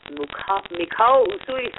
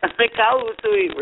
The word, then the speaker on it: Mikao Usui: On March 19th at 7 p.m., we have Mikao Usui. I'm a Reiki master, and I can pronounce it correctly. Mikao Usui Reiki Crystal Awakening with Dear Heart Energy.